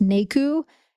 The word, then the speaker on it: Naku: And I would love to hear from Manari, because he also has a sanctuary for ecotourism and healing retreats called, I believe it's pronounced NAKU, N-A-K-U.